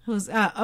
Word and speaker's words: juzgado: Juzgado.